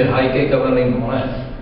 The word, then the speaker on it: Heike Kamerlingh-Onnes: Ano, ten pán, který za to může, se jmenuje Heike Kamerlingh-Onnes, takhle se to dokonce vyslovuje, holandský fyzik, nalezen Groningenu.